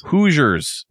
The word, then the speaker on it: Hoosiers: Hoosiers? Hosiers? Which way is that pronounced? – Hoosiers.